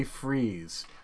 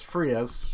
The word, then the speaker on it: Freas: So my next guy is going to be Frank Kelly Freeze, whose name I mispronounced for years. As Frias, mm-hmm. It looks like Frias, F-R-E-A-S.